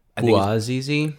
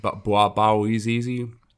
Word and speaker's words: Bouazizi: So when this stirred up in the Tunisian media, you had um, what Mohammed Bazizi, Bazizi, I can't pronounce his last name, Bawazi. – Bouazizi? – Bouazizi.